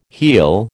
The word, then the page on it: heal: American English